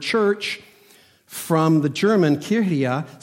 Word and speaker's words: Kirche: The German is spelt K-I-R-C-H-E, and so you can see where we get C-H-U-R-C-H kind of right from, but the actual pronunciation in German is Kirche. I'm going to try to get that right this time, Kirche.